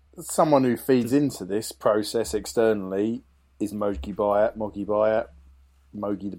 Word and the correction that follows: Mogi: Moji, I think is the pronunciation. – Right, thank you, confirmed.